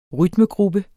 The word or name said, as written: rytmegruppe